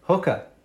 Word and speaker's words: Hoka: I had some Hoka shoes that Hoka, how do you say that word? – Hooker.